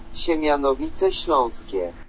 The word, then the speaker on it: Siemianowice Śląskie: Siemianowice Śląskie.